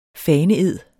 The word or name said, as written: faneed